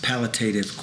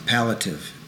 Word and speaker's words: palliative: The color pink relieves anxiety and stress because of the calming effect and its palliative qualities. I said that wrong. Palliative. P-A-L-L-I-A-T-I-V. Palliative.